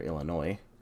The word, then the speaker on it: Illinois: He was born on February 6th, 1911, and he was born in Tampico, Illinois. Or Illinois. The S is silent.